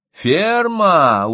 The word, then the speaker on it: Ферма: — Ферма! Ударение на последнем слоге.